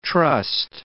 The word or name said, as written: trust